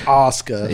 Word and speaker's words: Asuka: That's just Asuka.